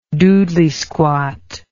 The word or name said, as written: doodlysquat